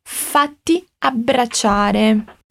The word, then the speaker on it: fatti abbracciare: Fala comigo prestando atenção na pronúncia do ti e do ci, ti e sei, fatti abbracciare.